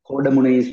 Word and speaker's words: Koddaimunai: Kodamuni Sports Village, that's how it pronounced.